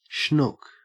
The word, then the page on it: schnook: Australian English